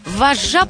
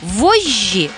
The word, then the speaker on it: вожжи: «Орфоэпист, специалист по нормам произношения, должен рекомендовать старшую норму «ж». В нашем случае это «вожжи», «вожжа» и сказать, что допустимо младшее «ж».